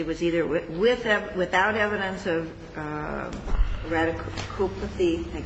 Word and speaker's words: radiculopathy: Radiculopathy. – Radiculopathy.